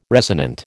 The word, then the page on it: resonant: American English